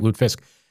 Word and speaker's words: lutefisk: That's how they say it, lutefisk.